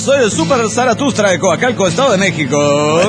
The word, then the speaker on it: Zaratustra: Es Zaratustra.